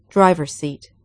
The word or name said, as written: driver's seat